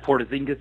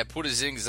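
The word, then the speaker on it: Porziņģis: Porzingis. – Yeah, Porzingis, that's it.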